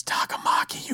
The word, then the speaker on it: Takamaki: And I'm like, it's Takamaki, you motherfuckers. Like, I thought I was going crazy. And then I went and saw a GameSpot video and it was like, some people think that the pronunciation in Persona 5 is a little bit off.